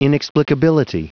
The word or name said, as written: inexplicability